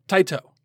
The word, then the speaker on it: Tite: It is spelled T-I-T-E. It is pronounced Taito.